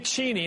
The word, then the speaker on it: Cheney: Dick Cheney, and that's how you pronounce his name, was out last night in black tie along with his...